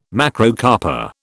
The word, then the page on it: Macrocarpa: British English